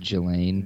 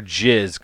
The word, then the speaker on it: Ghislaine: I believe it's pronounced Ghislaine. – Ghislaine. I was going to call her Jiz, because it's G-H-I-S. Ghislaine. – Ghislaine. – Ghislaine. – I'm pretty sure it's pronounced Ghislaine. I think the S is silent.